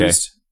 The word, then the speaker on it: Takei: Takei. George Takei. – It rhymes with gay. That's how you remember it.